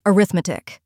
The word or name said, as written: arithmetic